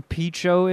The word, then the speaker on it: Pinchot: Jeff, do you know who Bronson Pinchot is? Is that how you say his name? Of course.